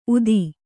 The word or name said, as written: ಊದ